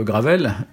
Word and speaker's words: gravel: Alors moi, je suis un petit peu plus euh, j'ai plus un œil euh, exercé sur le gravel, donc j'ai regardé le, la, géométrie du, du gravael. Je sais pas si on je prononce bien. Gravel, bah, c'est comme Raphaël. Gravel, voilà, Gravel.